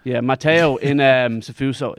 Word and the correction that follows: sfuso: I hope I'm saying that correctly. Fuso. – Sfuso.